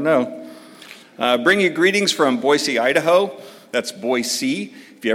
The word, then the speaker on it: Boise: And it's pronounced Boise, not Boise. If you say Boise, they know you're not from around there.